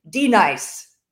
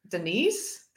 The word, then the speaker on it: Denise: The teacher's like, D-Nice. D-Nice, are you here? And this girl's like, Denise?